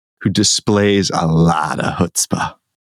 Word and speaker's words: chutzpah: Actually, I don't think it's pronounced chutzpah. That's how it's spelled. Oh. I think it's pronounced chutzpah. – Oh, it's chutzpah. – On this thing, it says chutzpah. It rhymes with foot spa. Chutzpah.